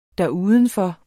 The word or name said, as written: derudenfor